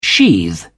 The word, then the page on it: sheathe: British English